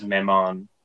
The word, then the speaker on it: Mammon: Is it Mammon? Is that how it's pronounced? – Yeah, I believe it is. He says Mammon.